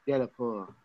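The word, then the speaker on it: Delapore: That's how you have to say it. It's French, not Swedish. I don't know why they did that, but De Lepore.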